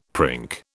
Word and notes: prink: British English

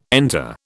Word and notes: enter: British English

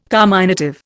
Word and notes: carminative: Indian

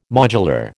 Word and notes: modular: American English

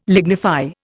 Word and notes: lignify: Indian

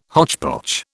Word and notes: hotchpotch: British English

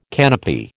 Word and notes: canopy: American English

